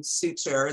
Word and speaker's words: Suter: So your actors, Adelaide, Clemens, and Leo Suter, is that the way he pronounces it? – Yes. Yeah.